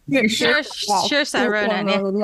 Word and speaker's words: Saoirse Ronan: Saoirse Ronan? – Saoirse Ronan.